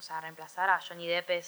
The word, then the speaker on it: Johnny Depp: No sé cómo se dice. Es Johnny Depp. – Nunca supe, ¿sabes? – Es Johnny Depp. – Johnny Depp, confirmadísimo. Pero bueno,